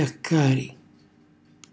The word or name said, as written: xjaccari